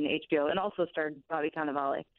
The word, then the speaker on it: Cannavale: And Bobby, is it Cannavale? Is that how you pronounce it? – Cannavale, yeah.